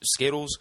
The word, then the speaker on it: Zkittlez: So it's pronounced exactly the same as Zkittlez, but it's spelled with the Z's there, so that's kind of a fun fact.